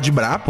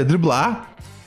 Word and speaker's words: driblar: E aí um dia eu vi esse cara, velho, esse homem de preto aí, e falou que eu não devia falar dibrar, porque a pronúncia correta é driblar.